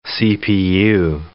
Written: CPU

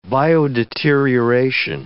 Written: biodeterioration

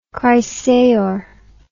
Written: chrysaor

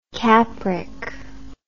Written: capric

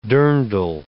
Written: dirndl

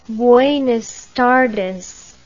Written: buenas tardes

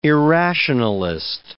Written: irrationalist

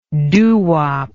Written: doowop

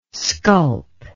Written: sculp

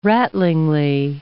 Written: rattlingly

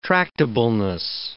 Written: tractableness

